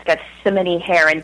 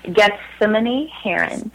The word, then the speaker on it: Gethsemane Herron: I am on, and my name is Gethsemane Heron, but that was a very admirable attempt.